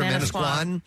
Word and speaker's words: Manasquan: Manasquan, is that how you say it? – Manasquan.